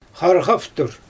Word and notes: xarɣavtur: Xyzyl

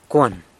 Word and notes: cuan: San Lucas Quiaviní

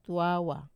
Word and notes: tawa: Wamesa

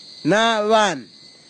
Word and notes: navan: Kwamera

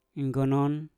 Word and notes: nggonon: Mpur

